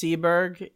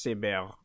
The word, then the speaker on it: Seberg: In English, it's Seberg. In French, it's Seberg.